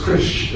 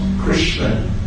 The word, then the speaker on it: Krishna: It's just Krish, it's not Krishna. It's like you've kind of amputated some of his limbs. It's not Krishna personally there in full.